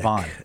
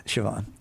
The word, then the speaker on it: Siobhan: Yeah, it's Gaelic. – It's pronounced Celtic. – Siobhan. – Yeah, Siobhan. You know what Siobhan means? – No.